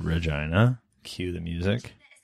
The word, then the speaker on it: Regina: It's Regina, actually. So you mispronounced it. You're probably going to have to bleep that out. – Sorry, Vagina. Vagina is the capital. Yeah, because it's a city that rhymes with fun.